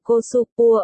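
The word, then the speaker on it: cosupua (コスプレ): được phát âm là cosupua ở Nhật.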